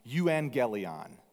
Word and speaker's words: euangelion: The root of both evangelism and evangelical is a Greek word that is pronounced, nobody speaks ancient Greek, but it seems like it's pronounced euangelion. Will you try to say that with me on three?